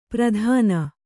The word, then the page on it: ಪ್ರಧಾನ: Kannada